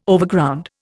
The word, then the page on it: Overground: Indian